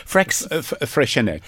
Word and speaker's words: Freixenet: And see, I would have got confused by that label because I always thought, how do you pronounce it? – Freixenet. – Freixenet. Okay.